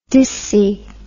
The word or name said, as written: disci